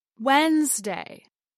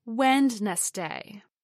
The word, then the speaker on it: Wednesday: And lastly, we have Wednesday, the third day of the work week, as Wednesday, not Wednesday.